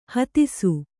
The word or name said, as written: ಹತ್ತಿಸು